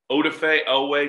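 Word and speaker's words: Odafe Oweh: Odafe Owe, I believe, is how you pronounce Jason's actual first and last name.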